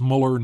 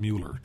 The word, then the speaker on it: Mueller: Well, we've had this discussion before, but it's Mueller, not Mueller, too.